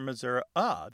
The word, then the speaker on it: Missouri: Because some Missourians don't say Missouri or Missouri-uh, they say Missouri, more like that E in get or met. – Yes. – And that readily lends itself to becoming a schwa.